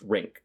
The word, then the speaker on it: RINCC: And I have a system for this as an acronym that pronounced RINCC, R-I-N-C-C.